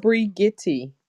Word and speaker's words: Brigittie: It's pronounced Brigitte.